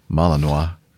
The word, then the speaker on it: Malinois: Thought this would perk the interest of Rick with his Belgian Malinois. Did I say that right? – Yeah. Yeah, that's right.